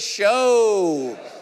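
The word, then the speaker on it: shew: Everyone say show. Notice in the King James, it's spelled shoo. All right. Shoo.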